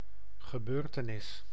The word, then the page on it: gebeurtenis: [ɣə.bøːɾ.tə.nis]; /ɣə.bø:ɾ.tə.nis/